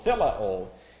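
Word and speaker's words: theleo (θέλω): This is T-H-E-L-E, long O, theleo, is used.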